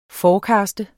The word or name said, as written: forecast